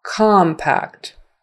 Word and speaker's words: compact: Anytime COM is the stressed syllable, then it becomes ah, compact, compact, compact, compact.